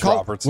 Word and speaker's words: Robertson: Jason Roberson. – Is it Roberson or Robertson? – I don't know. I say it wrong. – I feel like it's Robertson. – It's Robertson.